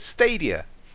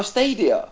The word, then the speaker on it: Stadia: How do you pronounce it? – Stadia. – Oh, Stadia. – Like stadium. It's the Greek name for stadium.